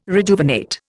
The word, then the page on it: rejuvenate: Indian